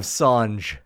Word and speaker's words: Songe: The Desolation of Song. Or actually, I mispronounced it. TK Jingles Live 3, The Desolation of Songe.